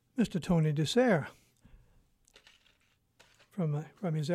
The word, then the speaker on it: DeSare: His last name is spelled D-E-S-A-R-E. Tony DeSere.